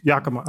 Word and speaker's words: Yakima: I can't say this right. You might know Yakima, Pasco, Richland, Kennewick, Washington, New Orleans. – Yakima. – Okay, got it.